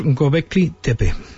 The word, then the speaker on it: Göbekli Tepe: Sí, esto se pronuncia más o menos Göbekli Tepe.